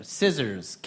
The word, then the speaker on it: scissors: Crayon, stapler, scissors, calculator. – Scissors. – And the silent letter? – C.